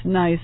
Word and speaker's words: gneiss: It's pronounced Gneiss. It's G-N-E-I-S-S.